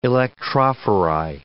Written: electrophori

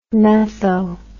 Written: metho

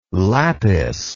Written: lapis

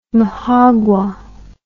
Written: majagua

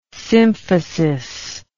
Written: symphysis

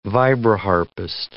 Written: vibraharpist

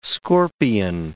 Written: scorpion

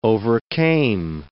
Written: overcame

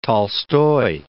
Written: Tolstoy

